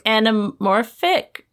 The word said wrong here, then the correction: anthropomorphic: Wait. – Anamorphic. – Wait. – Why do I feel like there's another syllable? – I feel like we didn't say it. Anthropomorphic. – That's it. – There we go. – Anthropomorphic. You know, furries.